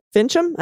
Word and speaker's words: Finchem: As you said, it's been a, a long red district, long time red district, but this particular Republican, Mark Finchem, I think I'm saying that right. Yes, that's correct.